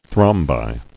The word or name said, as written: Thrombi